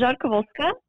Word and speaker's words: Rzadkowolska: It's Rzadkowolska, but you don't have to worry about it.